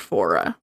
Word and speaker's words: Voirrey: By his wife Margaret and their 13 year old daughter Voirrey, which is spelled V O I R R E Y, but I'm pretty sure it's pronounced Fora.